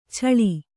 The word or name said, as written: ಚಾಳಿ